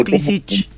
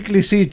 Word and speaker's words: Klisich: Todos le decimos Klisic, ¿no? – Sí, sí. Todos le decimos, yo me inclino así, Klisic, sí. – Bueno, pero es Klisic. En Yugoslavia siempre se pronuncia primero... fuerte la primera sílaba.